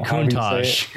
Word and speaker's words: Countach: The Contouche or the Countach. – Countach. I always say it wrong.